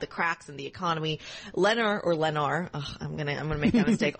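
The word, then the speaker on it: Lennar: You did have a, or I've been told it's pronounced Lenar. – Yeah, I think it's Lenar. – I've heard it both ways, but let's go with Lenar. – Yeah. Okay. – L-E-N is your ticker, up 1.2%.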